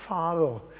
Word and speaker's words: Faro: Do you know, I've been to Pharaoh too. Well, I've mispronounced it. I've been to Faro in Portugal.